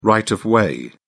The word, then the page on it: right of way: British English